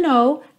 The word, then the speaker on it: know: I hope you know, I hope you know. Repara que o K da palavra know é mudo.